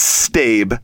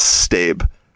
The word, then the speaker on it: stab: It's stab. Like, it's short for stabilization room, and it's pronounced stab.